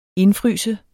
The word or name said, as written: indfryse